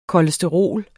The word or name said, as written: kolesterol